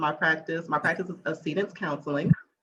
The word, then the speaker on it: Accedence: And accede has a meaning, you know, for me, and people call it accidents. It's not pronounced accident.